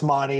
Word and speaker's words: Mauney: No, it's Mauney.